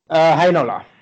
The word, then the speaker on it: Heinola: And to answer your other question about Newhook and Heinola. Is it Heinola or I heard it pronounced Heinola. How did you pronounce it? – Heinola. – Oh, it is that.